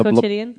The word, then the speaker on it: Quotidien: Wait, do you work at Le Pen Quentin? Is that how you pronounce it? – Yeah, Quotidien.